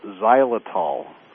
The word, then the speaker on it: xylitol: And that's something called xylitol, and it's spelled with an X. It's X-Y-L-I-T-O-L.